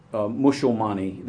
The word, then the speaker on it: Musulmani: The enduring idiom of Musulmani, this is the Bangla pronunciation,